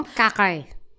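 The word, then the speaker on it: Poincaré: Point carré. – Excuse me? – Point carré.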